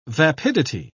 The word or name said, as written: vapidity